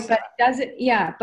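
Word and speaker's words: Eostre: Is it Istre? – Yeah, it's Easter. It's Yostre, right? Yostre. But don't worry. You can say, there's so many different ways of pronouncing these goddesses. Like there's I looked up so many of them and they're so different. So it is actually Yostre.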